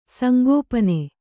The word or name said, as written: ಸಂಗೋಪನೆ